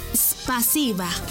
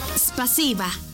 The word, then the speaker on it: Спасибо: En ruso, gracias se dice Спасибо. ¿Cómo? Спасибо. Una vez más. Спасибо. Y se escribe Спасибо. S-P-A-S-I-B-A.